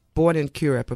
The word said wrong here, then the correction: Curepe: a place called Curep, which I learned now is actually pronounced Curepe.